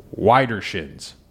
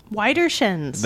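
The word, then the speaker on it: Widdershins: So this one, I believe it's pronounced Widershins. – Widershins. – That or Widershins.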